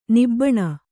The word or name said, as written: ನಿಬ್ಬಾನ